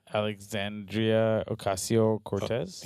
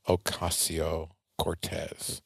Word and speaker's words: Alexandria Ocasio-Cortez: Alexandria Ocasio-Cortez? – Yes, yeah. Man. Ocasio? – Ocasio, yeah. – Ocasio-Cortez.